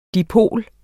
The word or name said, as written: dipol